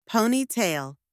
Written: ponytail

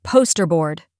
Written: poster board

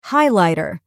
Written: highlighter

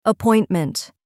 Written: appointment

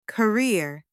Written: career

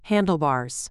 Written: handlebars

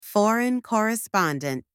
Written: foreign correspondent

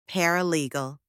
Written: paralegal

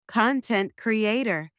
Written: content creator

